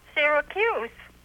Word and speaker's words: Syracuse: I'm obviously a radio announcer, and when I come to the name of your city, I always wonder whether I should say Syracuse or Syracuse. How do you pronounce it in your city? – Syracuse.